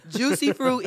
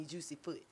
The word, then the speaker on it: Juceefroot: My Instagram is I am Juicy Fruit, and it's spelled we know how to spell that part. – Yeah. – Juicy Fruit is spelled JUCEEFROOT. Because I've had people call me Juicy Foot.